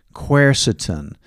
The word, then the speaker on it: quercetin: It's kind of a hard spelling, hard pronunciation too, but quercetin.